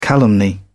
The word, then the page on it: calumny: British English